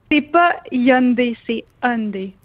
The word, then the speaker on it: Hyundai: Est-ce qu'on t'a dit, en terminant, comment bien prononcer le mot Hyundai? – Parce que c'est pas tout le monde qui l'a, là. – Ouais, des fois, on entend Hyundai, Hyundai. – Hyundai? – Ouais, Hyundai, oui.